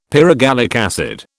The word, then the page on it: pyrogallic acid: British English